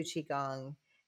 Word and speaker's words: Qigong: So, having said all of that, one of the things that that we visited about is you teach, Qigong. Am I saying that right? – Yeah. – Okay. – Qigong.